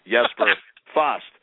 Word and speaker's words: Jesper Fast: and it is Jesper Fast. In case you guys were wondering. – Jesper. – Jesper, wow. – Even though it's spelled with a J, it's pronounced Jesper.